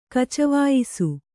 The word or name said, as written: ಕಚವಾಯಿಸು